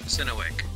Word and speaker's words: Synowiec: It's Rich Synowiec.